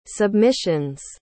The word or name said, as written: submissions